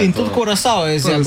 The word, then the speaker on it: Curaçao: Druga je bila naslednja, no, Nemčija, Curaçao. – Pravilno se zdaj izgovori Curaçao. – Curaçao, aha.